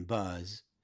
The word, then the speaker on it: Buzz: She said, "Hello, bud," She said it with a D, Bud, and not with a double Z, as in my name, Buzz.